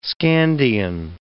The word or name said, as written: Scandian